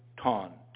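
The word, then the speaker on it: ton (τόν): Three-letter word, tau, omicron, nu, or as we would pronounce it, ton, T-O-N.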